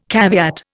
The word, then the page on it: caveat: Indian